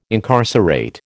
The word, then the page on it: incarcerate: American English